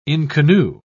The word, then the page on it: inconnu: British English